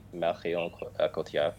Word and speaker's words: Marion Cotillard: But then we wouldn't have Marine Cotard. Is that how you say her name? – Marion Cotillard.